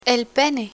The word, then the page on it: pene: Spanish